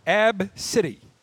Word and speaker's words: Abcde: Abcade? – The mother said it's pronounced Ab-City. A-B-C-I-T. Ab-City. A-B-C-D-E.